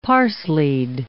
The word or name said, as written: parslied